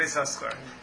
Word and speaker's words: Yisachar: No, this one also, but we pronounce it with both sins. Tzvi Yisachar.